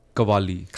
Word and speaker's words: Qawwali: So, and just to interject here, should I pronounce the W as a V? Qawwali? – Qawwali. – Qawwali, okay.